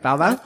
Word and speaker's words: baobab: I think I'm saying that right. Baobab? – Yep, baobab.